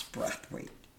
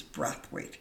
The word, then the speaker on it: Brathwaite: She says to me, first she starts, your name's Braithwaite. I said, no ma'am, it's not, it's Brathwaite. No, it's pronounced Braithwaite. I said, no, it is not, it is Brathwaite. My grandfather's been pronouncing it all these 60 something odd years, it's Brathwaite. Okay, so you Spanish?